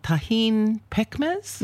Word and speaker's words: tahin pekmez: It's called tahin pekmez. – Mm-hmm. – Am I saying that right? You said it right.